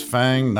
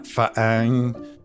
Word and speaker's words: FANG: Still pronounced FANG, not FAANG.